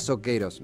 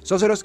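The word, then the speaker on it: Socceroos: yo, yo le decía Soqueros, me faltaba la pronunciación correcta en inglés. Sóceros,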